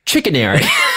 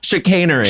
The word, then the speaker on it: chicanery: Chickenary. Chickenary. – Chickenary. – And then all of us burst out laughing. Laughed at this fucking woman. – Wait, I already forgot how you really say it. – Chicanery.